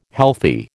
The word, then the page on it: healthy: American English